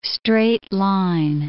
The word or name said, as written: straight-line